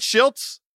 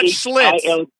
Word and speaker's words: Schilt's: Am I saying it right? Schilt's? – Yeah, Schilt's. – Schilt's, not Schlitz. Yeah, no, not Schlitz, the old beer, but Schilt's.